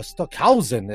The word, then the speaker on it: Stockhausen: Olha só, é, é, é, o próprio Stockenhau, uh, uh, Stockhausen, é assim que se pronuncia Stockhausen.